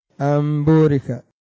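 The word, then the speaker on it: أن بورك: أمبورك. سميع بصير. مش أن، أم.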